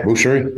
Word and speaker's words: boucherie: Yeah, boucherie.